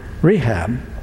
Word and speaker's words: Rechab: And they said, no, because our father, Rechab, is how you say it, he commanded us never to build a house, never to drink anything from the vine, and to live in tents.